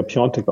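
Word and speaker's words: Piątek: der ja Piontek ausgesprochen wird, obwohl er Piatek geschrieben wird.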